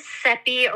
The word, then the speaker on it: Seppi: So my name is Seppi, rhymes with Peppy is what I say.